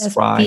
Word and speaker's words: Spri: I know several, you know, Perform Better and Spree, however you pronounce that, Spry. – S-P-R-I. – S-P-R-I.